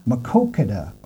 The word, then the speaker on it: Maquoketa: I know you have a river, and I'm really going to butcher this name, but it's called North Fork Maquoketa? Is that? – Maquoketa.